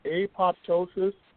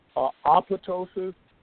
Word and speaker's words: apoptosis: For several years now, this expression, apoptosis, and for your listeners, you might hear it pronounced apoptosis, apoptosis. It really doesn't matter.